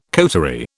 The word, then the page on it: coterie: British English